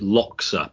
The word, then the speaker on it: Loja: I think I pronounce it Loxa, but I'm not sure. It's spelled L O J A in Ecuador.